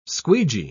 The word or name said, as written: squeegee